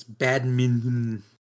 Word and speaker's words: badminton: It's there's an N in the middle, one T, and the British say badminton, and if you were to pronounce it like in California, there's like no T or D sound. It's badminton. Badminton.